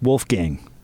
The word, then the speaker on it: Wolfgang: I believe it's pronounced Wolfgang. – Wolfgang. – Not like a gong? Like a giant cymbal? – Well, like Wolfgang. You know, no, it's German Wolfgang.